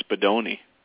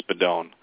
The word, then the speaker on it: Spadone: Well, obviously, when they're with Italians, they'll say Spadone. They used to say Spadoni. – Spadoni. – You know, my sisters would say Spadoni in school.